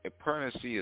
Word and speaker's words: pernancy: and pernicy is spelled P-E-R-N-A-N-C-Y, pernicy.